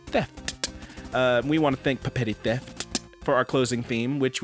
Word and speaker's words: Papetti Theft: Yes, that is how it's pronounced. Two P's at the beginning, two T's at the end. Papetti Theft.